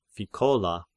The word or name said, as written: Phichola